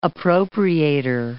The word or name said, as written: appropriator